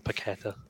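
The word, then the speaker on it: Paquetá: I remember like Plaqueta having like a really big like run on the break. – Is that how you say his name? – Yeah, I am going to go with that, Paqueta.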